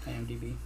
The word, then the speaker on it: IMDb: What I don't think you know is something I saw on IMBD, which is... – IMDb. – Yeah, did I say it wrong? – IMBD.